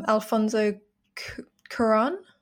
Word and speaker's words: Alfonso Cuarón: So, Itu Mama Tambien, 2001, Alfonso Cuarón. – Yeah. – Is that how you say it? – Yeah.